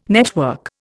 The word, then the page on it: network: Indian